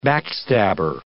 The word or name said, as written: backstabber